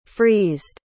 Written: freezed

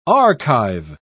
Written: archive

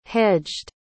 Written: hedged

